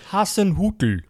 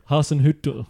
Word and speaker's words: Hasenhüttl: which is Ralph Hasenschüttl. Yeah, how's my pronunciation? – You just completely bottled it then, didn't you? – Go on, your turn. – Hasenhüttl. – Hasenhüttl.